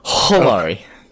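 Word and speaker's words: Edwards-Helaire: It's more guttural. It's Hilary.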